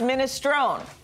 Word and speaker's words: minestrone: It's pronounced minestrone. – E. – No. – Minestrone.